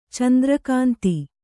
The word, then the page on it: ಚಂದ್ರಕಾಂತ: candra kānta